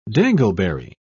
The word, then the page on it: dangleberry: British English